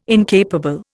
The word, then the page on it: incapable: Indian